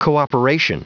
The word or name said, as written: cooperation